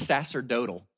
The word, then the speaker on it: sacerdotal: The word is sacerdotal.